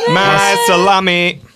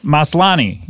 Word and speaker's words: Maslany: We have Tatiana Maslany. Nope. – Maslany. – Maslany.